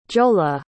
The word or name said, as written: giolla